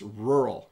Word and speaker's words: rural: It's even hard for me to pronounce the word rural. To have an R, a U, and then another R right away is very difficult, but it's rural. And we say it really quickly.